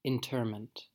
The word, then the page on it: interment: American English